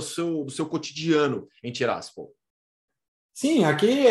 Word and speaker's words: Tiraspol: Tiraspol é a pronúncia correta.